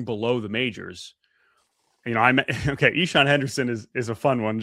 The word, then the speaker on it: Ixan: Just because it's an audio format here, Ichan is how you pronounce IXAN.